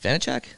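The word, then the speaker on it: Vaněček: Is it Vanacek? Is that how you say it? – Yeah.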